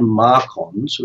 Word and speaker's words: MARCoNS: And that's something you can also read about online, but they're called Marcons, which is spelled MARCONS.